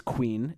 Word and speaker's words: quean: That word is queen, but spelled Q-U-E-A-N. Even though this queen is pronounced just like the word for a king's wife, and even though they derive from the same root, Q-U-E-A-N is not just a variant spelling of Q-U-E-E-N.